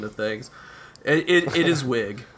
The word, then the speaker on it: Wiig: You know, like, I think a good example of a character who was definitely putting on a voice was Kristen Wiig, or Wiig, I think is maybe correct. – No, I think it's Wiig.